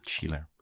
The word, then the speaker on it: Schiller: Hoe spreek je dat uit? – Schiller.